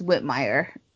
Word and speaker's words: Whitmer: It's Whitmire. – Right. – I'm from the Midwest, trust me.